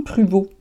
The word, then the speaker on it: Pruvost: Euh, oui, dans cet épisode-ci, je voulais parler d'un livre que j'ai lu, d'un livre qui a été écrit par Jean Pruvot. Euh, Pruvot, ça s'écrit P-R-U-V-O-S-T.